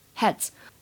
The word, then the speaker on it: heads: If we put our heads together, if we put our heads together，整句话呢发音技巧注意一下这个 heads，它的末尾呢这个 ds 字母组合发的是 z 这个音，heads heads。